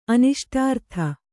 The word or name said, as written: ಅನಿಷ್ಟಾರ್ಥ